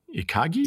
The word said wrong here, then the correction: ikigai: Oh, no, sorry, ikigai, is it? Yeah, I think ikigai, reason to live.